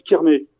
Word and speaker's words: Kerners: que en fait, ça s'écrit Kerners, effectivement, mais D'accord. – ça se prononce Kerné.